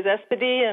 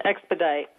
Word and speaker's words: Expedite: Yeah, it's pronounced both ways, Espedi and Expedite.